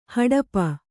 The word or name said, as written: ಹಾಟಿಪ್ಪೆ